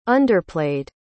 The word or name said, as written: underplayed